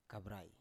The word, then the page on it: kavrai: Biak